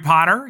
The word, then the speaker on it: Potter: My name is Jerry Potter. Yes, it rhymes with the boy wizard.